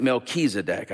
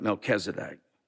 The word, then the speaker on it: Melchizedek: Some pronounce it Melchizedek. I've always pronounced it Melchizedek.